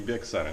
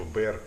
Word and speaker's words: Bexar: Но они произносят не Bexar, они как типа Bear County. – Да. – Там X не читается как будто.